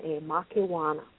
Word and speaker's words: Makewana: And this is the line of female oracles who called, their title was Makemana. Sorry, Makewana. – How do you spell that? – Makewana, Makewana.